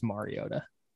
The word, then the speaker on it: Mariota: Well, then you need to pronounce his name right. It's Mariota. Mariota. I apologize.